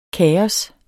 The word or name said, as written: kaos